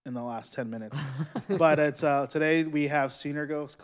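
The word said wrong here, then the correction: Sunergos: Coffee. Did I say that right? – I think it's Sunergoss.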